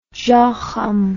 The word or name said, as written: Jochum